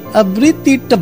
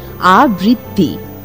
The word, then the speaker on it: আবৃত্তি: তবে কথা কি জানো, চেষ্টা চরিত্র করলে ওরকম গলা কাঁপিয়ে আবৃত্তিটা বৃত্তি আমরাও করতে পারি। – তা হয়তো পারো, তবে উচ্চারণটা হবে আবৃত্তি আবৃত্তি না।